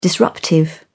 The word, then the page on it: disruptive: British English